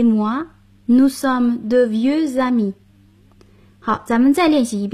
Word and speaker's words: amis: Jean et moi, nous sommes de vieux amis. – 我和 Jean 是老朋友了。复数形式的朋友就是在 ami 后加上 s，但并不发音。